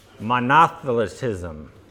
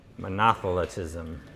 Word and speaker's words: Monothelitism: So the error is called Monothelitism. – Say that again. – Monothelitism. Yeah. I prefer monothelitism, but that is not the proper way to say it, apparently.